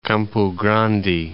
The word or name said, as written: Campo Grande